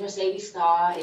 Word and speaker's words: Mercedez Starr: Well, my name is Mercedez Starr.